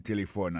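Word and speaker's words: telephone: N'a pas remarqué que l'accent tonique l'a tombé sous premier syllabe là. Si nous besoin de dire bien, c'est téléphone. Alors, ou prononcer ou appuyer plus sous premier syllabe là qui c'est T.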